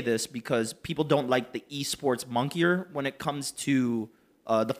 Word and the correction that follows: moniker: Is that how you pronounce that word? Is it moniker or monkey? – Moniker. I'm pretty sure it's moniker.